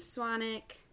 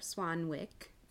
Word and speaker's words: Swanwick: So James Swanwick, or Swanwick, for those of you who want to pronounce the W in his name.